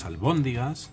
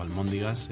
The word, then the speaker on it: albóndigas: Como último, último kit de la cuestión, eh, Deciros que las albóndigas se escriben con B, pero también está reconocido con M, así que quien diga almóndiga o quien escucha almóndiga, aunque a mí me suena fatal, pero que sepáis que se ha instaurado tanto en la población que al final la Real Academia eh, decidió contemplarlo como una pronunciación adecuada.